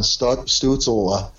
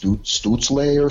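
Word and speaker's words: Stützle: I think I'm pronouncing that right. I'm hearing other people say it differently. Like you hear the German pronunciation, it's Stutzle or something. – Yeah, I can't say that way. So I stick with Stutzel, North American version.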